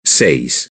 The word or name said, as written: seis